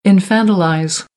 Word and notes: infantilize: American English; ə

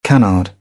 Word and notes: canard: British English; ə

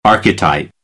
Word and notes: archetype: American English

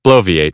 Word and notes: bloviate: American English; oʊ

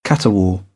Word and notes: caterwaul: British English; ɔː

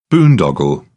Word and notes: boondoggle: British English; ɒ

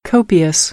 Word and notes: copious: American English; oʊ

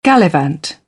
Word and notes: gallivant: British English; ɪ

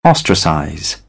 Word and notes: ostracize: British English; ɒ

